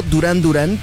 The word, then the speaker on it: Duran Duran: Con el tiempo simplificaron el nombre y se quedó Duran Duran, tal cual, como si fuera durazno, casi casi, Durán.